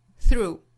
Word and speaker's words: through: 注意 through 这个词里面包含一个辅音连缀呢，就是 th 和后面的这个 r，那连在一起要发成 thr thr thr thr，在后面跟一个长音的 oo，就是 through through through。这个词我们不要读成了 through 啊，应该是一个音节一拍，如果在两个连缀的辅音中间再去加一个声音的话，就变成了两个音节了哈。